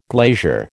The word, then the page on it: glazier: American English